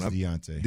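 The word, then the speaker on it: D'Onta: And running back Dante Foreman. – Mm-hmm. – Is it Deonte or Dante? How does he pronounce that one? – Deonte. – Deonte. I've heard it two different ways.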